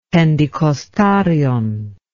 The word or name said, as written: pentecostarion